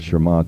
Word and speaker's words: Shema: But also isn't a part of Shema, and it's spelled S-H-E-M-A, but pronounced Shema.